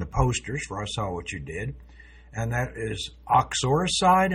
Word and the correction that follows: uxoricide: Am I saying that right? Uxoricide? – Uxoricide.